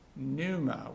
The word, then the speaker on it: pneuma: It's the Greek word pneuma with a silent P in the front.